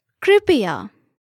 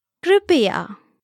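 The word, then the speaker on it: Kripya (कृपया): This is Kripya, Kripya. Let's break it down by syllable. Kri, pi, ya. Now let's hear it once again. Kripya.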